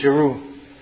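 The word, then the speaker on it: Chru: Jeru is spelled C-H-U-R-U, but it's pronounced with a J, Jeru.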